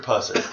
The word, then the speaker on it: Pusser: and it's a sheriff named Buford Tusser. who when he... – Are you sure you're saying that right? – Buford Pusser. Okay. Yeah, B-U-F-F-O-R-D. – B-U-F-F-O-R-D.